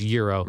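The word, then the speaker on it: Jiro: It's called, I think it's J-I-R-O. – Okay. – I think it's pronounced Euro. – Right. – It's called Euro Dreams of Sushi.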